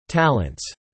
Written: talents

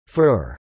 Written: fur

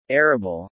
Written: arable

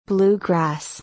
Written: bluegrass

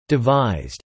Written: devised

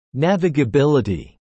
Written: navigability

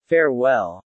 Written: farewell

